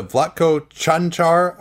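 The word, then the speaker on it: Čančar: Denver, a couple of guys, Vlatko Chanchar, I believe is the pronunciation, that had been a draft pick of theirs in the second round a couple of years ago.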